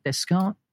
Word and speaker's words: Descartes: How do you say that? – Descartes.